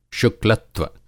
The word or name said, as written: शुक्लत्व